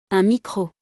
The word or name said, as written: Un micro